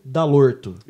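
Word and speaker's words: Dall'Orto: Valdir Dalorto.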